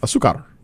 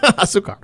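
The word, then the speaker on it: azúcar: Asuka. – Close. Just add R at the end. You're perfect. – Asukar. – There you go. – Asukar.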